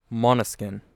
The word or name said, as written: Måneskin